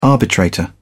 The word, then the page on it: arbitrator: British English